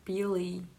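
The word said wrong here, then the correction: Bele: No, it's Belle.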